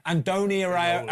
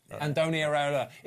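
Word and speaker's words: Andoni Iraola: Andoni Areola. Andoni Areola.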